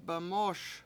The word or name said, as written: bemOOS